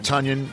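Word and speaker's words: Tonyan: Robert Tunyon. Tunyon rhymes with Funyon.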